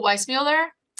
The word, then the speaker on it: Weissmuller: Oh, Weissmuller.